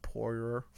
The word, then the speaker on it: Poirier: Versus Dustin Poirier, and I'm I'm glad I've watched a few of his fights to know that his name is Poirier, not Poirier.